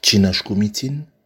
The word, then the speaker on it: Chinashkumitin: Je vous dis Chinashkumitin, ai-je bien prononcé? – Oui, absolument.